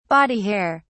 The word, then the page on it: body hair: British English